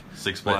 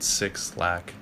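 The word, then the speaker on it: 6lack: I pronounce it Six Lack. – Yeah. – Which is just weird. I feel like everyone does that, but I think it's actually pronounced Black.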